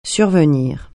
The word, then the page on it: survenir: French